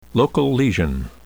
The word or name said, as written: local lesion